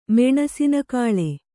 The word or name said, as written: ಮೆಣಸಿನಕಾಳೆ